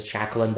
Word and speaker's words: Jackelynne: And so I chose Jacqueline, but it's spelled Jackelynne.